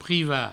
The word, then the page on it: privat: provençal